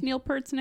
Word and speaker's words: Peart: How did you pronounce Neil Peart's name? – Peart. That's how you say it.